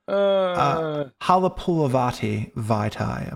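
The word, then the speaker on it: Halapoulivaati Vaitai: Taylor Decker at left tackle, Jonah Jackson at guard, Frank Ragnew at center, Halapalati Vaitai at right guard, and Penny Sewell at right tackle. Halapulavati Vaitai. Apologies. – Thank God you pronounced it correctly.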